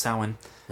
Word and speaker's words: Samhain: It looks like it's spelled Samhain, but it's actually pronounced Samhain.